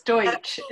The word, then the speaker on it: Deutsch: So today I could not be more excited uh, than to welcome my friend Elena Deutsch, and please correct me if that's not the way you pronounce it. – It's um, fine. You're very close. Deutsch. It's a hard one. it's got a um, lot of consonants.